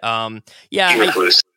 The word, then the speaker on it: Eberflus: Eberflus, right, is how you say it? – Eberflus.